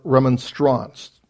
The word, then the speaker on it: Remonstrance: which leads us to the Remonstrance.